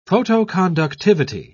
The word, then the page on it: photoconductivity: British English